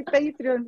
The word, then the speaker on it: Patreon: I say Patreon, yeah.